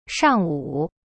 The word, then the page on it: 上午: shang wu